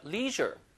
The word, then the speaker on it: leisure: leisure. 자, 발음이 leisure가 아니라 leisure라고 해요. 제 발음 보세요.